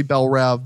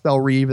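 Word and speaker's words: Belle Reve: But it's now officially pronounced Bell Reve, and that's what you'll hear in the movie, from what I understand.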